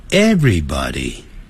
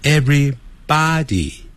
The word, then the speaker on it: everybody: 这样的话大家都觉得说你来自东方你很有礼貌跟我们西方人一样的都会打招呼好然后接着 everybody every 跟 body 声音由高由低这样下来 everybody 千万不要念成 everybody everybody 每一个身体更可怕的是变成每一个尸体 Body 除了代表身体以外也代表尸体好可怕所以念这个词的时候是 everybody 是一个词，而不是把 every 跟 body 分开，所以特别记住 Hi everybody， Hi 大家好。